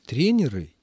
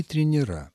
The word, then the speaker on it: тренеры: Валентина Зосимовна, для обоих слов правильной, абсолютно нормативной, литературной является форма с окончанием «ы» и с сохранением ударения такого же, как в единственном числе. Тренер – тренеры, диспетчер – диспетчеры.